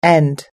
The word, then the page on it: end: American English